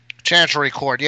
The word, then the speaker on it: Chancery Court: They have filed complaints in the Chancery Court, is that how you say it? – Chancery Court, yes.